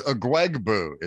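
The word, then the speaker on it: Ugwoegbu: Last name I'm going to go with Oogwoob. – It is a mouthful. It's a lot to stumble over. It is Agwegbu is his name.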